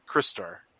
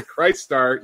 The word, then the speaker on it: Crystar: Crystar. – Thank you for pronouncing the name of that property correctly. As our fearless leader, called the Christ Star, even though he's a crystal.